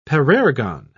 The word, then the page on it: parergon: British English